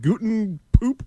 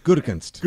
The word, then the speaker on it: Gutekunst: Who would ever, like, could you imagine if you called that Guten Poop, whatever his name is? – Gutekunst.